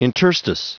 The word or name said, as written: interstice